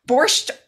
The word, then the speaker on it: borscht: What is the main ingredient in a classic Russian, borscht soup? B-O-R-S-C-H-T, soup.